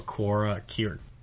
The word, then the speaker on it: Quora: For Cura? Is that how you pronounce the website? – Quora.